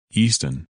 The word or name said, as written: Easton